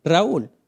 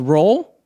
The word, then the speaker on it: Raul: And see, right now I'm saying it with the correct pronunciation in my culture. It's Spanish, right? Raul. Go ahead, say it with me. Raul. See, I've been called other things. I've been called Roll. Could be for my COVID way. I've been called Rule because I'm awesome, right?